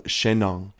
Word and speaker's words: Shennong: There was an emperor by the name of Shennong, and that is pronounced, or that is spelled, I should say, S H E N N O N G.